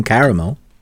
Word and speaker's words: caramel: I don't pronounce the second A, so it's caramel, which is funny because here in Northern California, we also have caramel, which is spelled the same way as I say it, caramel, C-A-R-M-E-L. But if I was talking about the city, it would be, I know, right?